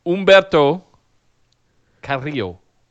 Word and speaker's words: Humberto Carrillo: Say Humberto Carrillo again. – No. – Please. – Humberto Carrillo. Am I saying it correctly? – Yeah, it's close enough. It's good. Thank you. – I rolled my R's, right? – It was very good.